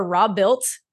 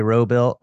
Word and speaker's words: Robuilt: Listen, I'm just honored that you said it right. Most people say Robilt, and I'm like, you don't watch the channel, but you know, I appreciate that.